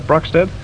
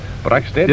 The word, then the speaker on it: Broxted: Is that Broxted they pronounce that? Broxted? – Broxted, yes.